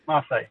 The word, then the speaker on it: Marseille: It starts, well, it's on the Mediterranean, it rhymes with day, and it's the oldest city. – Marseille. – Marseille. Marseille is the name of the city.